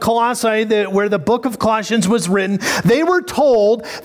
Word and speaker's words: Colossians: Colossians. – Colossians.